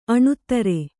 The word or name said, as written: ಅಣೂತ್ತರೆ